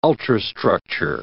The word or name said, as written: ultrastructure